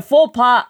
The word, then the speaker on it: faux pas: Then I made a faux pas again!